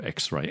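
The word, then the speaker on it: Ex:Re: The album's called X-Ray, and it's spelled EX-RE, but it's pronounced, I'm sure, X-Ray.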